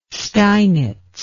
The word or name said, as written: steinitz